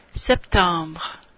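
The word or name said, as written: septembre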